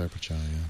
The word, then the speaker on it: Sundar Pichai: And now the guy that's running that company, Sundar Pichai, is that how you say his name? – Yeah, Sundar Pichai, He's good.